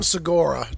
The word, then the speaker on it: Segura: I think Tom Segura. I think that's how you pronounce his name. I think you pronounce it like the national park.